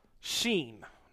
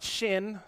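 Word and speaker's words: Sheen (שין): We've made it down to the letter Sheen. Sheen, not shin. Some of your Bibles say sheen and seen. It's not shin and sin, it's sheen.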